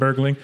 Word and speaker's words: Bergling: So, yeah, to kind of focus back in on Avicii, his real name is Tim Bergling. Is that how you pronounce it correctly, Ben? – Yes. – Tim Bergling.